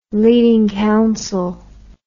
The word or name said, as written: leading counsel